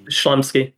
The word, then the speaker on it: Chlumsky: Is it Chlumsky. – Yeah, she's in Veep too. – I never know how to say that. And it's, I think it is Chlumsky. – Chlumsky.